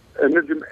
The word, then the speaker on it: Nejim: My name is Sleyman Najim. – Sleyman. Yeah. Sleyman. Okay, so, Sleyman. And you pronounce it Najim? – Najim, N-E-J-I-M.